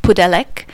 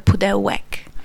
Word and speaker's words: Pudelek: So we, we have a little bit of a laugh at our own surname in my family because um, our uh, surname in Australia is pronounced Pudelek with an L because that's how it's spelled. Um, and uh, in Polish, it would have a hyphen either above it or through it, making it a W sound, which would be a Pudelek.